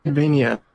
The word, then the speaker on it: Metroidvania: Metroidvania.